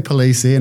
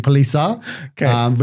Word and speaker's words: Palise: Well, I say Palise and we had this chat beforehand, but when I was in Italy a couple of months ago, they said it was actually Palise, which I find hilarious because my partner's called Lisa and we're engaged.